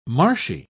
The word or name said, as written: marshy